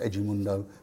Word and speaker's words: Edmundo: We went to this Vasco da Gama friendly because they just signed Edmundo, the Brazilian international Edmundo, pronounced Edmundo, as we discovered that night.